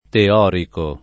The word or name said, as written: teorico